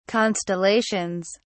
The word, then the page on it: constellations: British English